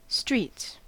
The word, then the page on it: streets: American English